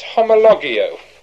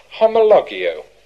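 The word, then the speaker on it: homologio (ὁμολογέω): The second one, I understand it's homologio. Homologio. I spell it H-O-M-O-L-O-G-E-O.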